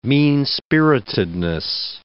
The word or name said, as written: mean-spiritedness